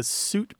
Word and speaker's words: suite: There's a there's an interesting fact that the suit pronunciation used to be one of the old pronunciations of S-U-I-T-E. So you did have two pronunciations of that word, but they both coexisted and they fixed themselves in different parts of the English-speaking world.